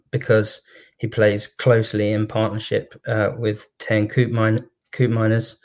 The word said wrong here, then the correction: Koopmeiners: I've just butchered his name there. It's more like Koopmeiners, but I can never say it without reminding myself.